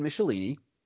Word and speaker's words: Michelinie: And a guy named David Michelinie, rhymes with Pick My Nini,